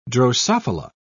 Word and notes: drosophila: British English

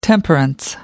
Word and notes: temperance: American English

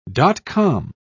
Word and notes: dot-com: British English

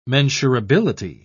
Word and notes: mensurability: British English

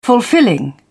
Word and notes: fulfilling: British English

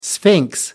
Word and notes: sphinx: American English

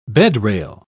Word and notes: bedrail: British English

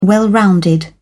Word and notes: well-rounded: British English

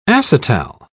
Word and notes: acetal: British English

